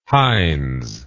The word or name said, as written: heinz